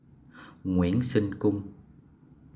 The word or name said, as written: Nguyễn Sinh Cung